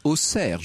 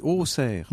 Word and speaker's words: Auxerre: On prononce Auxerre, je le dis parce que dans de précédentes émissions, j'avais eu le malheur de X-er cette X, et évidemment, j'ai eu beaucoup de messages. – On dit Auxerre.